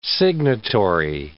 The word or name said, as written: signatory